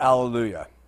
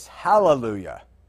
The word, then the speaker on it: Alleluia: Let's talk about Alleluia. Of course, the Greeks, that's how they say it. The Hebrews say, the way we would say it in English, Hallelujah. And it means, praise ye Jehovah.